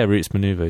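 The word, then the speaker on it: Roots Manuva: Yeah, Roots Maneuver, yeah.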